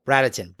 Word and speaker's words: Bradenton: Bradenton. – Bradenton.